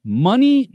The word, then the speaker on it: Mani: Pronounced money, but it's spelled M-A-N-I for those of you on the podcast that can't see them.